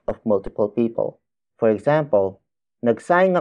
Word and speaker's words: nagsaing: Word for word, it's nagsaing ako para kay Tita Sylvia. Nagsaing ako para kay Tita Sylvia.